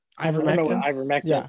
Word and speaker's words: ivermectin: Ivermectin. Yeah.